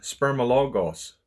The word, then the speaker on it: spermologos: And this babbler word, it's a Greek word, and it's pronounced spermologos.